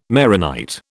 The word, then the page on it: Maronite: British English